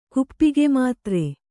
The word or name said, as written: ಕುಪ್ಪಿಗೆಮಾತ್ರೆ